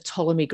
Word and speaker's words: Ptolemy: So this is The Last Days of Ptolemy Gray. Spelt with a P, you don't say it, apparently. I've been saying it wrong all these years.